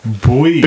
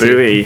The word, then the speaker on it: buoys: Do Americans pronounce boys? – Buoys. – Buoys. – Buoys, that's Bowie. – It. – Buoys. David Bowie.